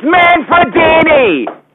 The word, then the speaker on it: Manfredini: And the composer's name is Harry Manfredi? Manfredi! It's Manfredini! Manfredini! Rhymes with Winnie!